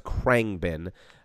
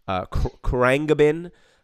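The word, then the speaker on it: Khruangbin: They are called, I believe it's pronounced Krangbin. Krangbin. It's K-H-R-U-A-N-G-B-I-N. They're a current group that almost sounds like 70s funk.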